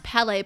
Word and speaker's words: Pele: Okay, so the card we got today, perfect as always, was Palais, it's pronounced Palais, but it's spelled P-E-L-E, which is the Hawaiian goddess of volcanoes.